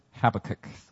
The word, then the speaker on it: Habakkuk: People have different ways of pronouncing this book, but I pronounce it Habakkuk. So that's what you're going to be hearing all day today.